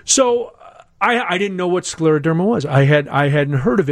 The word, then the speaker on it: scleroderma: Let me introduce Stephanie Gresh, who is the executive director of the Scleroderma Foundation of Greater Chicago. Am I saying it right? Am I getting anywhere close to the correct pronunciation? – that was very close and very correct. Right. Scleroderma.